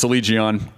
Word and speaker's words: Allegaeon: I never say their name right, and I don't think I say their name right in the interview, but it's Allegaeon. I said it wrong again.